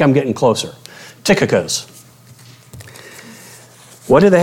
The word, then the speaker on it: Tychicus: If some of you have been around me, you've probably heard me pronounce that Tychicus. And when I was working with Carl on that, he corrected me. The CH is actually pronounced with a K.